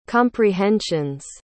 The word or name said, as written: comprehensions